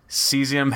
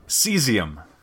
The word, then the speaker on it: cesium: Since 1967, blah, blah, blah, blah, blah, Einstein, blah, blah, blah, blah, blah, cesium has been widely used in atomic clocks. Cesium. Cesium. I said it right. I always had it right.